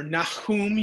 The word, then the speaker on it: Nahum: Because by the time of Nahum's day, or Nahum, you want to pronounce it with that chet, Nahum.